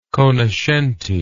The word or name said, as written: conoscenti